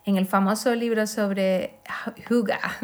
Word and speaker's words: hygge: Hace unos años se puso de moda el término danés Huga, Se pronuncia así de feo, Huga, pero se escribe con H, Y, G, G, E, algo que leeríamos como hige o haige.